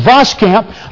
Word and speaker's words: Voskamp: And as we think, there's a great book that a lady by the name of Anne, and I believe she pronounces her name, Voskamp, wrote.